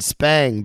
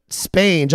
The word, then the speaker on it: spange: And, we had no idea what that word meant, but now I do, actually, because we were mispronouncing it. We were saying spang, but spange, I've heard that.